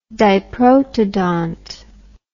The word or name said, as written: diprotodont